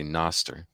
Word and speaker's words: Nostr: That's that's the one exception where I don't say Noster because it's kind of the way it's spelled out.